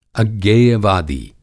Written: अज्ञेयवादी

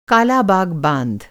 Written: बाँध

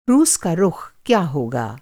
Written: रुख़

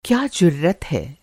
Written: जुर्रत

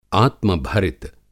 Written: आत्मभरित

